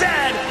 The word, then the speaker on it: zed: Chesterfield is a coach, and it is pronounced Zed, not Z-Zed.